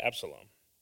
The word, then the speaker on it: Absalom: And here, David, many say that this is was written by David during the time that his son, is it Absalom? Did I say that right? What's that? Did somebody say it? Absalom, right? Yeah, Absalom.